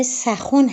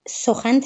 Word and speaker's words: سخن: اصلا در فارسی باستان این واژه سخون هست به مرور در دوره پهلوی و به فارسی میانه که رسید این سخن تلفظ میشه